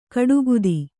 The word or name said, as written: ಕಡುಗುದಿ